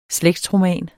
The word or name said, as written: slægtsroman